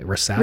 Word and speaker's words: Risavy: I think it's Rissavi. How would you pronounce that? R-I-S-A-V-Y. Rissavi? – Rissavi.